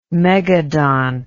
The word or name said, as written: megadont